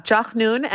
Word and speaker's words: Jachnun: And there's this other bread named, pardon me if I'm pronouncing this incorrectly, but Jachnun? – Yeah, Jachnun, and Fanta's bread is called Dabo.